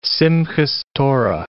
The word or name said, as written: Simchas Torah